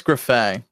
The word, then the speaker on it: Griffais: I don't know if I said that right, but I felt very confident. – I think it's Griffat. – Griffat? Still pretty good. Pretty good. And not a bad French pronunciation for me on that one.